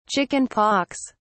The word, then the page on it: chicken-pox: British English